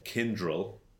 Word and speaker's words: Kyndryl: But I guess the biggest news, really, on the IBM side of the house is that Kyndryl, if I'm spelling that right or pronouncing that right, yep.